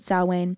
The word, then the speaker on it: Samhain: I think they pronounced it Samhain. It's not spelled that way, but that's how they said it.